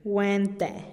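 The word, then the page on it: guen-te: Teotitlán del Valle Zapotec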